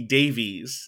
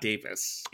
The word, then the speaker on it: Davies: It's pronounced Russell T. Davies, not Davis.